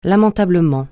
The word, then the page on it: lamentablement: francais